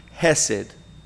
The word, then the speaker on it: hesed: We've talked about this word loving kindness, a very important word in Hebrew, hesed. H-E-S-E-D is how we would spell it in English.